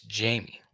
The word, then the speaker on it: Jaime: So it's not pronounced like the Jaime Spanish is spelled. It's Jamie. Cardell is at Colorado.